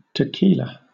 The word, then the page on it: tequila: British English